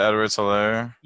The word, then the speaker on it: Edwards-Helaire: Clyde Edwards-Helaire.